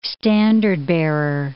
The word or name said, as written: standard-bearer